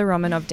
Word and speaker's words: Romanov: The last days of the Romanov dancers came about.